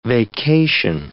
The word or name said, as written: vacation